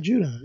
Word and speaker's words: Judon: Matt Judon, yeah.